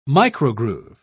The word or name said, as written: microgroove